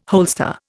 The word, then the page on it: holster: Indian